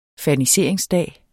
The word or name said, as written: ferniseringsdag